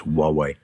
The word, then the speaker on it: Huawei: I don't think they pronounce the H, do they? It's Huawei.